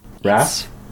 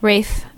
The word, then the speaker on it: Ralph: Besides Ralph Fiennes and his performance, but it's just We got someone telling us that it's pronounced Raph. – Rafe. – Okay. Yeah. – But because you say Fiennes right after, it just sounds like Rafe.